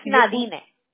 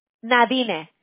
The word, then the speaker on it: Nadine: Se pronuncia en inglés Nadine, pero no hay modo como pronunciarlo en español. Le digo a mi mamá que fue un castigo que me dio un nombre, así que uno no puede pronunciar. Ah, se puede decir Nadina.